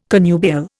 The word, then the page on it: connubial: Indian